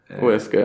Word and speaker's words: Huesca: Eibar, Huesca. Du får gärna rätta med mitt uttal. – Huesca, ja.